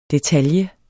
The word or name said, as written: DETALJEN